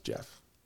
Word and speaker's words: Gef: G-E-F, but it's pronounced Jeff.